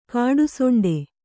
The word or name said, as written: ಕಾಡುಸೊಂಡೆ